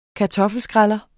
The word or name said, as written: kartoffelskræller